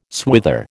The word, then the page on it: swither: American English